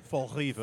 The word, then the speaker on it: Fall River: You pronounced it incorrectly. Isn't it Fall-Riva? – Fall-Riva. That's the right way to say it.